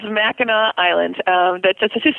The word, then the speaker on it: Mackinac: But it was always pronounced Mackinac.